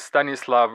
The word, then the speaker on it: Stanislav: And my name is Stanislav Wisniewski.